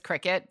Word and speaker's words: Cricut: I think you pronounced it right. I think it's Cricut, but it's spelled Cricut.